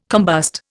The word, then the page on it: combust: Indian